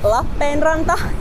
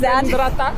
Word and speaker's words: Lappeenranta: Oh, it's a Finnish city called Lappeenranta. Try to pronounce Lappeenranta. – Oh, yeah, pretty good.